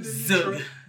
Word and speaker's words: Zug: Zug. – Z-U-G.